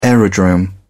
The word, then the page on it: aerodrome: British English